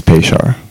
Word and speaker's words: Peshar: It's Peshar. – Peshar, see? It's like an S-H.